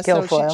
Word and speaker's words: Guilfoyle: And so Molly Jong, I think she quote tweeted, I don't know how to say her last name, Kimberly Guilfoyle. Is that how you say her last name? – Yes. – Yeah. – Guilfoyle.